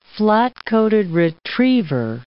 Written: flat-coated retriever

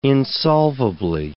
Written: insolvably

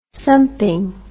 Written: sumpin

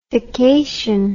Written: fication

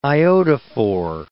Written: iodophor

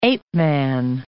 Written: ape-man

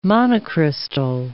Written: monocrystal

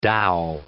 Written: dow

